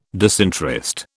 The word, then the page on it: disinterest: British English